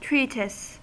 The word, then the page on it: treatise: American English